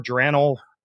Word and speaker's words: geraniol: Geraniol? – Oh, geraniol is how I pronounce it.